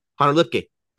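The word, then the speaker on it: Hunter Luepke: Also, I've learned that, how do you say his name? Hunter Lipke? – Hunter Lipke. Yeah.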